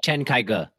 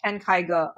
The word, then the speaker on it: Chen Kaige: Chen Kaige. – Yeah, Chen Kaige.